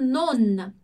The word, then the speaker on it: non: E depois a gente tem o non. Se a gente prestar atenção aqui na pronúncia do non, ele sozinho, olha como eu digo non. Non, eu digo a letra N no final, eu pronuncio ele.